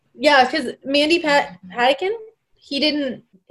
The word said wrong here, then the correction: Patinkin: He Mandy didn't, Patinkin.